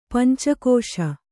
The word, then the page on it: ಪಂಚಕಸೆ: panca kase